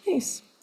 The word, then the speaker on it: Myss: It's spelled M-Y-S-S, although it's pronounced Mace.